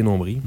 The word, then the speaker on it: nombril: Alors que nous, on dit sourcils et nombril. Mm. Sans prononcer le L.